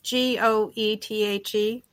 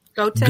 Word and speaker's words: Goethe: It's G O E T H E. Goethe. – Goethe. Goethe. – Goethe. – Yeah. Goethe is the way the Germans would pronounce it.